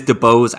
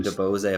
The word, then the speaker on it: DeBose: No, it is DeBose. Ariana DeBose. – DeBose. – And DeBose, apparently.